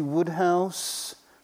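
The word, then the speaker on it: Wodehouse: You know, P.G. Woodhouse rhymes with good house, not roadhouse, as I remember.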